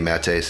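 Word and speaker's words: Maté: Matte, same thing as Twitter. Last name is spelled MATE, like mate.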